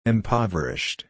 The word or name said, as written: impoverished